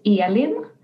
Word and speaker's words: Elin: It's the very Swedish way of pronouncing my name is Elin. But that is very hard. Yeah, that's very hard to pronounce in a sort of English way. Even I have trouble pronouncing it in the correct way within an English sentence. So if you say Elin or something like that, that is absolutely fine.